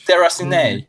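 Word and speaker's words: Déraciné: It's French. Deracine, Deracine, I don't know. – I didn't know that was supposed to be French. – Deracine, Deracine, it is.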